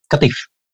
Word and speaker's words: Qatif: Again, from Saudi Arabia, born in, is it Qatif? Is that how you pronounce it? – Yeah, Qatif.